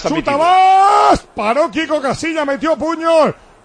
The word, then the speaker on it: Bas: No se dice Guas, ¿eh? Se dice Bas.